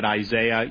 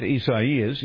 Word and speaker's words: Isaias: So, you know, I'm calling it Isaiah. You guys can call it what I know that's not how you pronounce this. It's something else. But it's weird, and I'm just going to keep butchering it for the next 72 hours, and I'd rather just be that. So, however you choose to say it, Isaias, he is gaining strength and expected to impact the Gulf Coast sometime tonight or early tomorrow as a Category 3 storm.